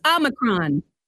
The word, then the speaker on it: Omicron: There's no N except for the very end. It's Omicron.